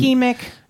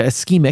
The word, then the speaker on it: ischemic: Now, they only found weak evidence that unprocessed red meat consumption is linked to colorectal cancer, breast cancer, type 2 diabetes, and isometric heart disease, and no link at all it's between. Ischemic. – Yeah, ischemic. – It's okay. Pronounce ischemic.